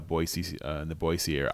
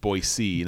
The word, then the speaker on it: Boise: My niece is a nurse in the Boise Idaho area. – Good pronunciation. – Yeah, right. Like that's how you know. That's how you know I'm in the know. I'm just Boise, not Boise.